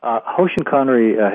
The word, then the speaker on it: Hoshin Kanri: And did I say that right? – You did. Hoshin Kanri has been called the world's most powerful planning and execution system.